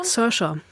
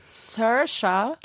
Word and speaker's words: Saoirse: Saoirse. – Saoirse.